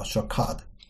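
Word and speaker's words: shakad (שקד): 那么这个留意呢，也是三个同样的字母，但发音有点不一样，叫 shakad。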